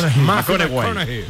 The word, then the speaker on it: McConaughey: ¿Mateo McConaughey? – Sí. – Es un futbolero, le gusta. Hay que ir corrigiendo la pronunciación. – McConaughey. Michael McConaughey. Bueno, pues el Mateo se pone.